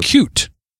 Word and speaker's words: Qt: So, which is actually pronounced cute. Nobody says it that way, but that's cute. What it's supposed to be. It's supposed to be pronounced cute.